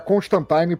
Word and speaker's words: Constantine: Era Constantine pra rimar com Valentine, por causa dos flertes dele com o sobrenatural.